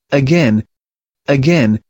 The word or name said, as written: again